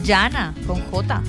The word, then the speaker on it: Jhanna: Yana, con J. – O sea, se escribe J-H-A-N-N-A.